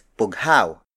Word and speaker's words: bughaw: The next native Tagalog color is bughaw. Bughaw. Bugao.